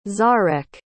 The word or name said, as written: zarek